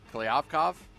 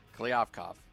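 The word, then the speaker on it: Kliavkoff: We're going to have more of the comments made by the new commissioner of the Pac-12, George Kliavkov. Is that how you say it? Sounds right. – Sounds dead on. – Klyavkov.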